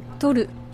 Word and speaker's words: Toru: Toru. – Toru Oui. – T-O-R-U.